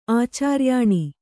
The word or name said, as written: ಆಚಾರ್ಯಾನಿ